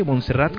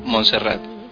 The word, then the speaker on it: Monserrat: Ernesto Monserrate, Monserrat, ¿cómo pronunciaría tu apellido? – Monserrat. Catalán, pero Monserrat es como, no sé, Monserrat.